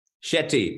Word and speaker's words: Shetty: Is that am I saying it right? – Shetty, but you did you did a good job for the first time.